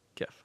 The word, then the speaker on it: GIF: Is this, how do you pronounce GIF? – GIF? – Yeah, GIF. Okay, good.